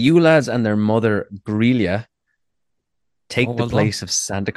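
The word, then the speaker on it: Grýla: It is Grilla. It is Grilla. That's Grilla. The only one I know the pronunciation for.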